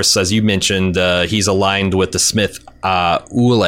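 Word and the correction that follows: Aulë: Is that how you pronounce it? – Ule? – Aule. – Aule.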